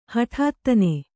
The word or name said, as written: ಹುಟ್ಟುತನ